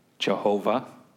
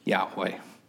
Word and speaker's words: YHWH: So they just went off of some things that they had, and some would pronounce it Jehovah, and some would pronounce it Yahweh.